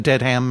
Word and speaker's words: Dedham: This is in Dedham, Massachusetts? – We pronounce it Dedham, but yes, Dedham.